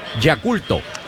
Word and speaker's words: Yakult: en japonés, Yakulto. Nos dijeron que esa es la pronunciación correcta, porque el equipo de donde viene Murakami justamente viene de ahí, de Yakulto.